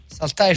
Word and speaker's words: Saltire: Si chiama Saltire Flag, si scrive Saltire, ma si legge Saltire Flag.